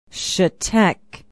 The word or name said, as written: chetek